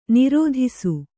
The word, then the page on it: ನಿರ್ದೆಸೆ: Kannada